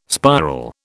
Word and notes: spiral: British English

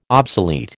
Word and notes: obsolete: American English

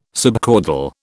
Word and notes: subcaudal: British English

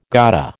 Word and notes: gotta: American English

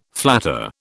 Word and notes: flatter: British English

